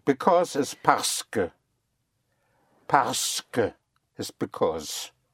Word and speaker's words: parce: The S into the park makes it parce. Parce. – Parce. – Parce. Yes. Right. In spelling, it's spelled P-A-R-C-E, Q-U-E, in two words, parce.